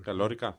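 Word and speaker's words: La Calórica: δεν ξέρω πώς το τονίζουν εκεί. Είναι λοιπόν 4 άνθρωποι. – Μάλλον La Calorica, ναι, μάλλον. – Καλόρικα. – Μάλλον, ναι. – Ωραία, θα πάμε με αυτό. – Γιατί βλέπω και τον τόνο.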